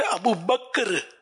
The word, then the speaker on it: ابو بکر: تو اصل تلفظ ہے ابو بکر ابو بکر, یہ رٹ لیں ابو بکر, مگر وہ چونکہ زبان پہ اتنا چڑھا ہوا ہے کہ وہ نکلتا ہی ابو بکر ہے لوگوں کی زبانوں سے.